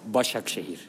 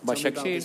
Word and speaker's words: Başakşehir: How do I pronounce it? – Başakşehir. – Okay. Başakşehir.